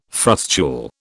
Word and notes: frustule: British English

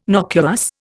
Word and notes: nocuous: Indian

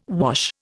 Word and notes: wash: Indian

